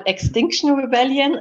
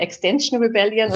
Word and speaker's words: Extinction Rebellion: Ähm, wir sind auf jeden Fall Extinction Rebellion, äh, weil viele sagen immer Extension Rebellion oder sonst was.